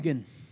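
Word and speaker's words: Heughan: Hugan. – Yeah, Dugan and Hugan. Sam Hugan.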